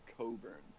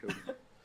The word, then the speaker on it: Cockburn: So, it's actually pronounced Coburn. – Oh, is it? Oh, shit. Yeah. – He told me that because I was like, dude, you're making this shit up. He was like, it's pronounced Coburn.